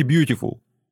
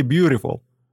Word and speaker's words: beautiful: Я говорю, вы понимаете, он думает, что произносит букву «Т», а на самом деле произносит букву «Р». Обманите его. Не говорите «beautiful». Скажите «beautiful». Они так и произнесли.